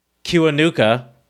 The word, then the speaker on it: Kiwanuka: Michael Kiwanaka, as I mentioned. Mm-hmm. Hold on, let me make sure I said that right. Kiwanuka, Michael Kiwanuka, is British.